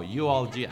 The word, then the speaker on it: eulogia: It's like a eulogy, eulogia. There you go, eulogia.